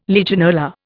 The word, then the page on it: Legionella: Indian